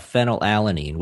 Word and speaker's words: phenylalanine: So you're talking about phenylalanine, which is an amino acid that that makes up the artificial sweetener aspartame.